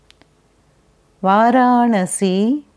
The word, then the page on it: Varanasi: Sanskrit